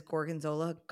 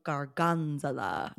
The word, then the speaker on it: gorgonzola: I have a friend who exclusively pronounces gorgonzola garganzola.